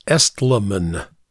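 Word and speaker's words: Estleman: I mispronounced his last name. It's Estlemann.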